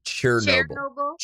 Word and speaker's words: Chernobyl: No, it's not like the singer Chernobyl. It's Chernobyl.